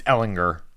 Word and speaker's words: Ehlinger: Hard G. It's Ehlinger.